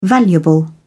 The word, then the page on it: valuable: British English